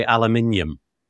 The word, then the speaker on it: aluminium: You say aluminum, but you don't. No, what is it you're missing? Aluminium. You don't have the I between the N and the U, do you? – Right.